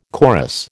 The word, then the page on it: chorus: American English